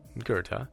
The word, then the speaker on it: Goethe: Did you know Johann Wolfgang von Goethe, pronounced Goethe and spelled G-O-E-T-H-E for those curious souls, was a German writer and scientist?